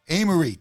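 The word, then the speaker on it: Amaarae: I don't know if it's Amarie or Amarae. – Amarie, thank you.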